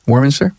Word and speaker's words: Warminster: Jim, you did a lot of years working for Automotive Training Center in Warminster. Warminster. Did I say that right? Warminster? PA? – Yeah.